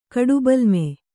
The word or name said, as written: ಕಡುಬಲ್ಮೆ